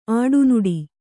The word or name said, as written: ಅಡನಾಡಿ